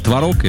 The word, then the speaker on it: творог: Если мы возвращаемся к нашему слову, скажу, что Надежда Николаевна, вот творог или творог завтра купит ваш любимый Илья. И с утра, чтоб сырнички. А? Хорошо? Правильно сказали.